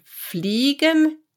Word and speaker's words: fliegen: IE is a long E in German. Let me say it to you. Wenn fliegen hinter fliegen fliegen, fliegen, fliegen, fliegen nach.